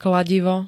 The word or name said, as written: kladivo